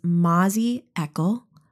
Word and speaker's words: Mazzy Eckel: Her name is Mazzy Eckel. Rhymes with freckle is what she reminded me.